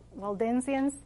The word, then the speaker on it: Waldensians: The Waldensians, is that how you pronounce yes. – it? In Spanish, it would be Valdenses. Okay, Waldensians. Some were shoemakers and would go house to house, and they would also give the truth of the Lord.